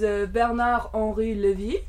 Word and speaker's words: Bernard-Henri Lévy: It is Bernard-Henri Lévy.